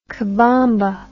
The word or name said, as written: cabomba